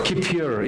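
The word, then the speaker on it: Kippure: Is that right? Kapur? Oh, no. What's that? Kapur. Here you go. There you go. That's the correct pronunciation.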